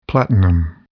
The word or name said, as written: platinum